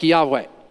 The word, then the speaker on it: Yahweh: And so most modern scholars today say, well, you actually pronounce it more like Yahweh, because that's how you would pronounce the Hebrew verb that is I am.